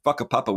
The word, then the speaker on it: whakapapa: Or equally in Aotearoa New Zealand, Maori culture, there's this beautiful concept of whakapapa, spelt with a WH but pronounced with an F, whakapapa, which is their idea of lineage, the idea that we're all in a...